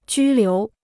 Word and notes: 拘留: jū liú

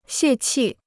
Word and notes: 泄气: xiè qì

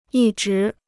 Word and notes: 一直: yī zhí

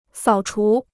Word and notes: 扫除: sǎo chú

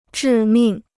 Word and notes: 致命: zhì mìng

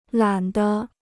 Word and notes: 懒得: lǎn dé

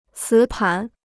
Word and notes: 磁盘: cí pán

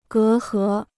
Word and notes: 隔阂: gé hé

